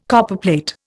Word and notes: Copperplate: Indian

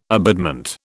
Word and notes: abutment: British English

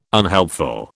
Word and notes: unhelpful: British English

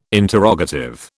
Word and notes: interrogative: British English

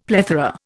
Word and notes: plethora: Indian

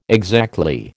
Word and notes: exactly: American English

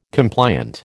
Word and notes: compliant: American English

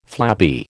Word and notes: flabby: American English

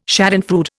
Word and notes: schadenfreude: Indian

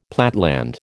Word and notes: platteland: American English

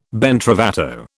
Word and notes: ben trovato: British English

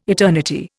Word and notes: eternity: Indian

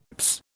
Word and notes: psst: British English

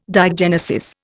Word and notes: diagenesis: Indian

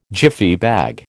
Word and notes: Jiffy bag: American English

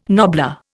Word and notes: nobbler: Indian

